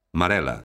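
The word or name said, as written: marelo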